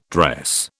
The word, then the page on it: dress: British English